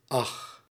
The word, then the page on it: ach: /ɑx/